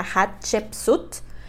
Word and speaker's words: Hatshepsut: Yo me trago y eso que he estado leyendo bastante de ella, pero se pronuncia Hatshepsut ella, les cuento que este es otro caso que no sabemos cuándo nació, pero se cree que nació alrededor del año 1507 a.C.